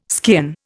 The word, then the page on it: skean: Indian